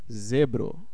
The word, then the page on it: zèbre: France; [zɛbʁ]